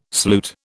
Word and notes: sloot: British English

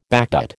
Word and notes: backdate: American English

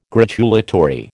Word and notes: gratulatory: American English

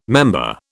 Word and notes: member: British English

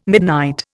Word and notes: midnight: Indian

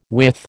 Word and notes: width: American English

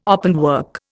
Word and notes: openwork: Indian

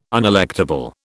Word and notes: unelectable: British English

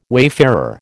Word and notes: wayfarer: American English

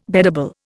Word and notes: beddable: Indian

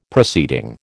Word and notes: proceeding: American English

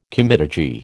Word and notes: comitadji: American English

